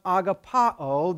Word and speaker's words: agapao: It comes from a Greek verb pronounced agapao that we call agape love.